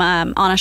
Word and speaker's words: Ana: Please, I know it's spelled A-N-A, yeah, but I pronounced it wrong. It's Anna.